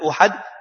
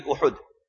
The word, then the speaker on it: Uhud: Many people pronounce it as Uhud. It is Uhud.